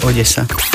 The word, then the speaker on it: Odesa: V slovenčine väčšinou D a E sa vyslovuje meko, de. A keď sa to má vysloviť tvrdo, tak je naznačená tvrdá výslovnosť. Takže ak tam nie je nič hrana tej zátvorke, znamená to, že sa vyslovuje Odesa.